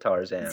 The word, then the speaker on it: Tarzan: Tarzan emphasizes the second syllable of Tarzan.